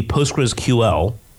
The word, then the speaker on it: PostgreSQL: The correct pronunciation is actually PostgreSQL. Okay. But almost nobody guesses that off the bat, which is why most people say Postgres.